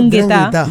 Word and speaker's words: 'Ndrangheta: 'Ndrangheta. – 'Ndrangheta. – Sí, lleva una N al principio, pero no se pronuncia, es 'Ndrangheta.